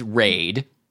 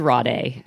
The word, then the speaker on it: Rade: So our hero is Raid, I assume that's how it's pronounced. – I thought it was Rade. – It's like Sade? – Uh-huh.